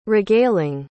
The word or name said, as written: regaling